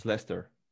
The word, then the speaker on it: Leicester: Yeah, and also like um, there are like foreigner traps in uh, everywhere, so you would have the city called Leicester if you read it as you've learned it in school. – Isn't Leicester? – It's Leicester. – It's Leicester.